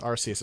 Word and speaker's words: Arceus: For a second, I thought you were stopping me because I pronounced it Arceus, but... Okay. – That's correct. Arceus is correct. – Arceus is, in fact, correct.